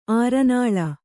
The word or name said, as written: ಅರನೆಲೆ